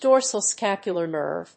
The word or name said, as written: dorsal scapular nerve